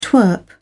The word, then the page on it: twerp: British English